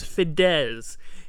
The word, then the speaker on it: Fidesz: Yeah, I just looked up how to pronounce it to make sure I was pronouncing it right. It's called pronounced Fidesz. Fidesz.